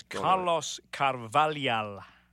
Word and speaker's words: Carvalhal: It's Carlos Carvalhal.